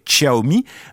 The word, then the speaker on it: Xiaomi: c'est la marque Xiaomi. Alors, je vous la fais à la française parce que c'est vrai que sinon, on va la prononcer Xiaomi, mais sinon, c'est Xiaomi écrit donc X. I-A-O-M-I.